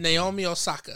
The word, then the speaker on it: Naomi Osaka: Naomi Osaka.